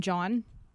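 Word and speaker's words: jawn: Are you familiar with the term John? Mm. Spelled with a W, J-A-W-N.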